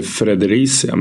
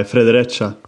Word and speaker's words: Fredericia: Hur uttalas laget? – Jag tror faktiskt i Danmark att de säger Fredericia med E-et. Litet... – Nej, Fredericia.